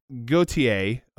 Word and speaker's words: Gotye: In French, that's Gotye, although it's spelled very differently.